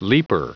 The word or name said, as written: leaper